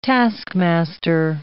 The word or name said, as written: taskmaster